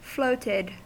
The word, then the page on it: floated: American English